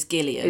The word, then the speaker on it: Gillian: So I say Gillian because in this country we say Gillian, but the way that she pronounces her name is Gillian.